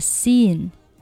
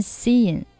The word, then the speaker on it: Sean: 下面 Do you want to try Sean instead? – 你要不要换 Sean 问一问啊 ?Sean, 好，注意这个人名呢 ,S-E-A-N, 读 Sean, 不要根据字面的啊，这个读成了 Sean, 很多朋友会读成 Sean, 那其实呢它是读作 Sean, 肖恩。